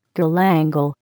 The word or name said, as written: galangal